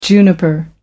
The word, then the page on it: juniper: American English